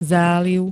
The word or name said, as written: záliv